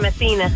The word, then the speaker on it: Messina: Is that how you pronounce it? Messina? – Here. – Yes. – You're Dina. – Yes, Dina and Messina.